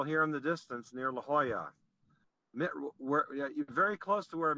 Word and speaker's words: La Jolla: Yeah, well, yes, La Jolla, right. Yeah, wow. The J is a hoy.